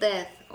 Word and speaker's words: Death: Sen dead diyorsun. – Hayır, The Death of Stalin. – Evet, telaffuzunda yanlışlık var onu diyorum.